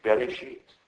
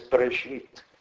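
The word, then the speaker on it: Bereshit (בראשית): Also ich habe zum Beispiel auch Zeichen mal angehört, eben gerade der Genesis am Anfang, da wird das wirklich Bereshit, da wird auch das erste E nämlich voll ausgesprochen. Eigentlich als Bereshit. Das erste E nach dem B, das hört man fast nicht.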